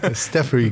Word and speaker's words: Staffery: Ist der Geschäftsführer, ist richtig, von Stuffery. Staffery, wir haben vorher noch gerätselt, wie spricht man es eigentlich aus? Staffery geht. Wir sagen einfach Staffery, genau.